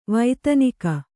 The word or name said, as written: ವೇಧನಿಕೆ